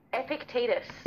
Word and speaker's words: Epictetus: Epictetus. – Epictetus.